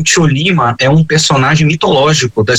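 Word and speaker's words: Chollima: Vamos nessa então falar sobre a Seleção Tcholima, falei certo? Seleção Tcholima? – Falou mesmo, Seleção Tcholima, a lendária, conhecida até hoje, mesmo que tanto tempo tenha se passado.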